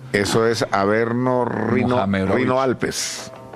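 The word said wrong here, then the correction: Rhône-Alpes: Rone Alpes. – O Rone Alpes, sí.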